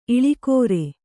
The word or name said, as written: ಇಳಿಕೋರೆ